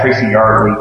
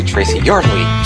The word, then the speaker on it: Yardley: We had Tracy Yardley doing commissions and selling comics. – Sorry, but you're saying the name wrong, so... Right? – Yeah, it's actually Tracy Yardley.